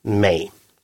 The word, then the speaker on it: mig: Такая форма написания является разговорной и просторечной. Более правильное также состоит из трех букв, только других. M-I-G. А форму разговорного написания она получила потому, что даже грамматически правильное написание читается все равно «мей».